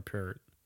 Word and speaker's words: Peart: So Geddy Lee, Alex Lifeson, Neil Peart. And I guess it's Peart, but I always pronounced it Peart. – For sake of argument, we'll just call it Peart.